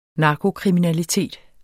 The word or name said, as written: narkokriminalitet